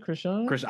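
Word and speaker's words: Chrisean: That's how you pronounce that name, Krishan? – I guess so. – It is.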